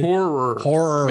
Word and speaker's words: horror: Horror. Make sure you say that right. Horror movies. – This morning I'm talking and I was telling my daughter Brianna, I says, hey, I'm going to be doing a thing on horror movies. She goes, what's horror? No, horror. Horror. – There's two syllables.